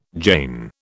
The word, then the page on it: Jain: British English